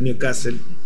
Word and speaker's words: Newcastle: Se llama Newcastle, las urracas para el castellano Newcastle, el nuevo castillo, porque la pronunciación en inglés es Newcastle.